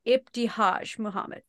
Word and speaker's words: Ibtihaj Muhammad: I just finished Proud, and I'm bringing this up because I mispronounced her name and I feel really bad. So it's Proud by Ibtihaj Muhammad.